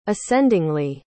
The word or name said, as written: ascendingly